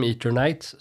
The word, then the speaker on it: Eternights: Nej, så det är egentligen bara Eternights som jag fortfarande tycker och trodde länge att det kanske borde uttalas Eternights istället eftersom Eternal, Eternal. Men nej, i spelet säger de Eternights så då säger jag Eternights.